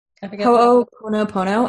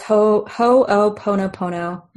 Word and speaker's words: Ho'oponopono: like the Ho'oponopono. – Ho'oponopono. I have it spelled wrong on the outline, but it's Ho'oponopono.